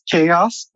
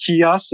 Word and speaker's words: Kios: Yeah, we're French people, so we thought it would be pronounced chaos, but we realized after it's actually pronounced chaos, so we're dealing with this right now. – So, so should I call it Kios Masons? – Please do.